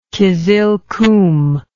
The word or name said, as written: kyzylkum